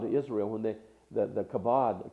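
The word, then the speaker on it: kavod (כבוד): It's called kabod. It's actually pronounced kavod.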